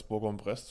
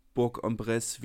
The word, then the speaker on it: Bourg-en-Bresse: Aus irgendeinem Grund wird das G in Bourg-en-Bresse wie ein K ausgesprochen.